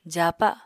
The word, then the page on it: jaapa: apatani